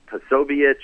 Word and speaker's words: Posobiec: They have fellows like Jack Posobiec, that's how you pronounce his name,